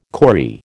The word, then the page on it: corrie: American English